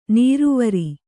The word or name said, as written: ನೀರುವರಿ